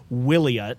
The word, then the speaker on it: Wiliot: So this company called Wiliot, I think that's how you pronounce it, W-I-L-I-O-T, Wiliot, has produced, let's see here, what do they call it?